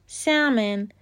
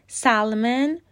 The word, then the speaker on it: salmon: تلفظ درستش سامن سالمن یا سالمون هیچ کدوم درست نیستن ال تلفظ نمیشه و سایلنته